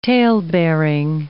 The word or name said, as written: talebearing